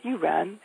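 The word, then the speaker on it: U'Ren: If you want to remember how to pronounce my last name, it's as if you were trying to call me a little bird specifically, a wren. So you would say, Andrea, you wren, as in Andrea, you are a wren.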